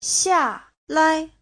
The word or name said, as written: xia4lai5